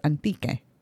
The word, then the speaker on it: Antique: So when the Spaniards arrived, the early Spanish chroniclers were apparently influenced by the French and recorded the name spelled as H-A-N-T-I-Q-U-E. They pronounced it with a silent H because of the French influence. – Mm-hmm. – But as we know now, it's pronounced as Antique.